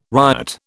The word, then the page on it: ryot: British English